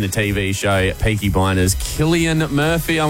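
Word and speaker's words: Cillian: I always say his name wrong, but I'm pretty sure it's Killian.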